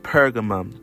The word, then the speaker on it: Pergamum: Then there's Pergamum, the city of, I believe it's also called Pergamus as well, Pergamus. It's spelled P-E-R-G-A-M-U-M.